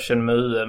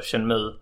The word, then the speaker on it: Shenmue: Jag köpte PlayStation 4 nu för att Shenmue. Många står sig på att jag säger Shenmue, men det heter ju då Shenmue.